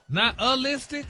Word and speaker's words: nihilistic: Stop putting the ooh in there. – Nihilistic? – There you go.